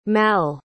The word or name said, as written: malle